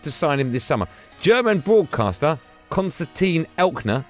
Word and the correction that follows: Eckner: is that how you say his name, producer Tom? – Eckner, so I got the first bit right? – Yeah.